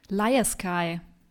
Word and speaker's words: Lyaskye: Lyasakü? Lyasake? Lyasake? L-Y-A-S-K-Y-E. – Liaskai. Ja. Liaskai.